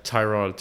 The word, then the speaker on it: Tyrod: Tyrod. I think is how it's pronounced. They said last year. Tyrod. Yeah, in an interview. – Then why is there a Y? – I don't know. – That makes no sense. – Last year, in like an interview, like he said it or someone else said it, and they're like, oh yeah, it's pronounced Tyrod. We just never correct you guys.